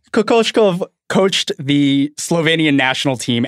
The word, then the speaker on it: Kokoshkov: And there was a lot of that when Kokoshkov was coaching.